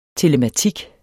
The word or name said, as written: telematik